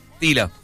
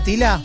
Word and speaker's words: Tyla: Es T-Y-L-A. – Sí, sí, la estamos viendo. – Ser Tila, entonces. – Tila. – Tila. Bueno, jovencita. – Usted dígame mi pronunciación que yo... – ¿Es esta, Tila? – Sí.